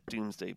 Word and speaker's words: Domesday: And well, it's spelled Domesday, so but lots of people pronounce it Doomsday Book, but it shows like the extent of the English colonialization of the area.